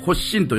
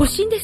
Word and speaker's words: 発心: まず読み方なんですけど、それで、はい、初の心と書いてね、これね、発心と読むんですわ。 – 発心ですか、はい、発心、はい。